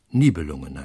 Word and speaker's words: Nibelungen: Ich sage gleich mal in Parenthese, die germanische Anfangsbetonung fordert auch im Deutschen, dass die Namen auf der ersten Silbe betont werden, auch wenn es sich nicht nur um zweisilbige, sondern um dreisilbige Namen handelt. Also Burgunder heißt es, Nibelungen heißt es, Walküre heißt es und nicht, ich verweigere mich der falschen Betonung auf der falschen Silbe.